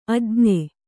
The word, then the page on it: ಅಜ್ಞೆ: ajñe